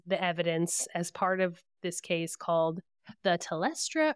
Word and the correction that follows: Telstra: or the Telstra. Sorry, I keep saying it wrong. Telstra Living Witness Project.